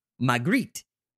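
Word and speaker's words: Maigret: the crime fighter Magritte.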